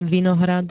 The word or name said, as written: vinohrad